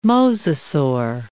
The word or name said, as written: mosasaur